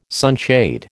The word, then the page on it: sunshade: American English